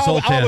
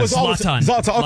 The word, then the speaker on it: Zlatan: Yeah, I used to call him Zoltan. – Zlatan. – Okay. Zlatan.